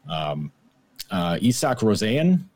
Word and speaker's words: Isak Rosén: Isak Rosén, that's how you pronounce it, we were told.